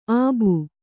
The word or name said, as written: ಅಬ್ಬು